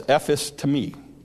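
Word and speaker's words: Epistemi (ἐφίστημι): If you look up the Greek word for the word instant in this verse, you'll find that it's pronounced something like this, "Epistemi," "Epistemi."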